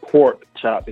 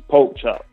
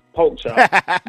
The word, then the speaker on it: Poke Chop: As you see, I'm saying No Swine because I want to get it through everybody's head that my name is not Pork Chop. It's Poke Chop. Every time I say my name, folks be like, oh, Pork Chop. Nah, nigga. Poke Chop.